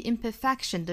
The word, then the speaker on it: imperfection: tell him that may be a good thing, 最后一句 because without imperfection, 这个 because 一般来说要弱读成 because, 也就是后面这个 cause 弱读成刷 sound, because 后面 without 和 imperfection 连读 without imperfection, 我们还需要注意 imperfection 的重读是在 fa 这个音节上啊，应该是 imperfection, without imperfection, neither you 英音的话可能会把它读成 neither neither，美音更倾向于读成 neither neither，跟另外一组就是 either either 是一样的。